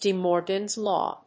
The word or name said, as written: de morgans law